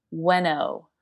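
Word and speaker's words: Ueno: All right, so for the record, for everyone who's listening, it is pronounced Weno. – Weno. – Yep, there you go. It's like it's like Bueno, but without the B.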